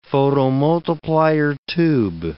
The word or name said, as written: photomultiplier tube